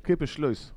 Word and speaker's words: Kippersluis: Ska jag hjälpa dig med uttalet? Ja. Kipperslöjs. Kippersluis. Uh, Kippersluis. Kevin van Kippersluis.